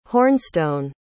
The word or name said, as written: hornstone